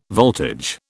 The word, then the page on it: voltage: British English